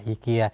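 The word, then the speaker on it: IKEA: อีเกีย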